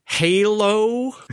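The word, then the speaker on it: HaLow: And AH, which is which they want somebody, some sort of industry consortium wants you to call this Halo. It's spelled capital H-A, capital L-O-W, Halo, which is also a low power variant, hence low.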